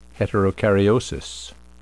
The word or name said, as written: heterokaryosis